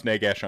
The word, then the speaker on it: Nagash: It is pronounced Nagash on Rantcast.